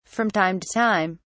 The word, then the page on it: from time to time: British English